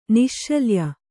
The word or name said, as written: ನಿಶ್ಶಲ್ಯ